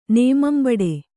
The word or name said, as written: ನೇಮಂಬಡೆ